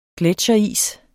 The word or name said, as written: gletsjeris